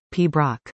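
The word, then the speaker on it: Piobaireachd: Witness the pinnacle of piping excellence at the Open Piabairich, pronounced P. Brock.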